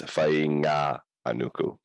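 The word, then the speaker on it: Fainga'anuku: Fanganuku. – So the trick with the Maori names is, if you see an NG, that's the start of a new syllable. So it's Fainga-anuku. So Fainga-anuku.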